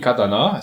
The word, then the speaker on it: Katana: Auf der neuen Suzuki Katana. Es wird jede Silbe gleich lang ausgesprochen.